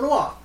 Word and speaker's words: noir: Film noir. You don't pronounce the R.